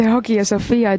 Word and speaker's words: Hagia Sophia: Kas sinul ütleb midagi, kas ma hääldan õigesti Hagia Sophia? – No, sina ütlesid seda praegu ilmselt kreeka keeles, et Hagia Sophia, et püha tarkuse kirik Istanbulis. Ja türklased on seda natukene türgistanud, nad ütlevad Hagia Sophia.